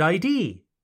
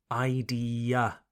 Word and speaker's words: idea: But in English, it is idea. Idea. Not ID. It's pronounced idea. Idea. Good idea. Idea is spelled, c'est écrit, I-D-E-A, I-D-E-A. That A on the end of the word is important and it must be pronounced.